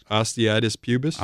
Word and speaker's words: osteitis pubis: Osteitis pubis? – Osteitis pubis.